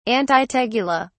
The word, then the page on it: antitegula: British English